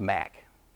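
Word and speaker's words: MAcc: And a master's degree in accounting is abbreviated capital M, capital A, little c, and it's pronounced Mac, you know, like mac and cheese.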